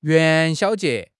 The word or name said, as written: 元宵节